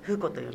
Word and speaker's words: 風子: まず風子の名前なんですが、風という字と太鼓の子と書いて風子と読みます。